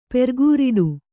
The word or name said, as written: ಪೆರ್ಗೂರಿಡು